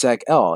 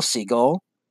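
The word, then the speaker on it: Seg-El: But it's viewed as an insult by Seg because it's like, you know, dude, you're saying my name wrong. It's Seg-L. He's like, oh, hello, Siegel.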